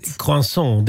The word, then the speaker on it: croissant: Croissang. – Croissang, det är croissant.